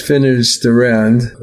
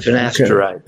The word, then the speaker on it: finasteride: which has finasteride as a... – finasteride.